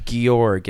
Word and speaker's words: Georg: I think it's going to be pronounced Georg. It's a it's George without the final E.